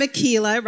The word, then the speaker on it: Makila: I am Makila, rhymes with tequila.